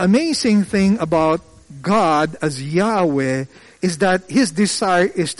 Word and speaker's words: Yahweh: The Hebrew word obviously would be YHWH, which we pronounce as Yahweh.